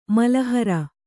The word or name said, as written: ಮಲಹರ